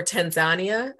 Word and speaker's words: Tanzania: I really want to go to Tanzania, or Tanzania is what I've been told is how you pronounce it.